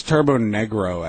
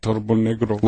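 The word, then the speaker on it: Turbonegro: I think it's Turbo Negro, actually. – Did they have a lot of problems with that name? – Well, I think they're just Norwegian, so that's how they say it, Turbo Negro.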